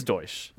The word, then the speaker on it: Deutsche: Wow, it is Deutsche. – Yeah, it's Deutsche Bank. – Yep. Deutsche Bank.